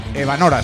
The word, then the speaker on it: Evanora: Evanora, sí, lo he dicho bien.